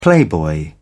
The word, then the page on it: playboy: British English